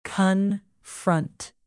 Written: confront

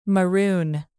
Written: maroon

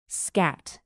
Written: scat

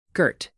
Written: girt

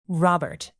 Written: robert

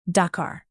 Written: dakar

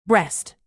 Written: breast

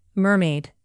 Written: mermaid

